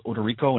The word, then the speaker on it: Odorico: Or you can reach me on, I'm on Facebook under Ted, and my last name is spelled Odorico, and it's O-D-O-R-I-C-O.